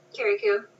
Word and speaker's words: Carriacou: Carriacou.